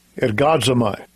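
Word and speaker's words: ergazomai: If they made manifest that they are wrought in God, the word wrought comes from this Hebrew word pronounced Strong's G 2038. Ergadzamai.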